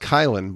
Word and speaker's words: Caylin: Kylan is actually spelled C A Y L I N. It's pronounced Kylan, but it's spelled Kalen, and I made that mistake when I called him and actually said Kalen, uh, forgetting that it was Kylan and how you pronounce it.